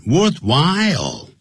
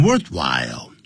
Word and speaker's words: worthwhile: C, 我们再看 worthwhile, 不要念成 worthwhile, 值得的或者值得花钱的或者值得努力的，通常是用 it is worthwhile to 加原形动词的用法，或者也可以加动名词。